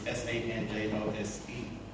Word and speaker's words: San Jose: It's S A N J O S E. Oh, you mean San Jose? you know, here in the States, uh, we pronounce J as, um, as H.